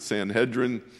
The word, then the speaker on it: Sanhedrin: The religious leaders of the day formed a council called the Sanhedrin, or some people will pronounce it Sanhedrin.